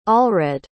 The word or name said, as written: allred